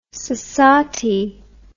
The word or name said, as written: sosatie